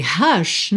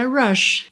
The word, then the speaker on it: rush: Só que esse rush, na verdade, é rush.